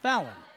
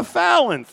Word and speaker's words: Fallyne: Varsity cheer, again, we go to, in no particular order this time, Kaylee Mierzwinski, Emily Santos, how would you pronounce that? Somebody help me. F-A-L-L-Y-N-E. – Fallon. – Fallon. – I'm going with Fallon. – Oh, Fallon. Thank you.